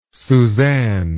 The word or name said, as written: susanne